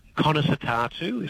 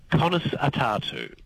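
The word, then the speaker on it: Conas atá tú: Conas Atatu is how you say that in Irish. – Say that again. – Conas Atatu.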